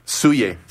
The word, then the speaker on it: Soulier: We just got to hear Geometric by Soulier. Did I say it right? – Soulier.